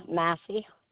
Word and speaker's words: Massey: Sandra Massey. – You do say 33024? – Uh-huh. – Spell that last name for me. M-A-S-S-Y? – M-A-S-S-E-Y.